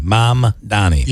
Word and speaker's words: Mamdani: I always say it wrong. Is it Mamdani? Mamdami? Yes. Mamdani.